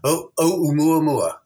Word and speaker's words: ‘Oumuamua: Because you pronounce it more correctly than. I'm going, I'm going to talk about the strange interstellar object Oumuamua.